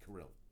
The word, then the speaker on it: Carril: Oh, it's Pete Carril, right? It's not Carroll. Pete Carril. Carril. Yeah, I always say that wrong.